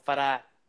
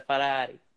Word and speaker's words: Ferrari: Fiat's part of Ferrari. – It is? Mm-hmm. I didn't know that. – Speaking of accents, if you want to say Ferrari, right, you got to go Ferrari. It's a Ferrari.